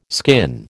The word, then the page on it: skin: American English